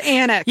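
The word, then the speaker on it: Alnwick: Alnick is pronounced Alnick and Ick, as in icky. – Yeah, I pronounced it Alnick. – Yeah. So it's Annick.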